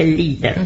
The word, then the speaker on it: Lieder: Cuando son muchos se dice leader. o Lieder se escribe, se dice Lieder.